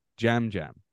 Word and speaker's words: Yam Yam: So he says it was a soft J. I don't know if that's his accent or I know it's spelled to us Y-A-M-Y-A-M, but it may be Jam Jam.